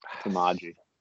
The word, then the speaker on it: Samaje: It spells exactly how you say it. Samaje.